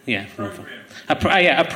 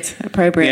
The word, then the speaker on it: opprobrium: Opprobrium. – Very good. Opprobrium.